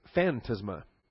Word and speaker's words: phantasma: From the Greek phantasma, phantasma, accent on the first alpha. So it's phantasma. Phantasma. P-H-A-N-T-A-S-M-A, phantasma.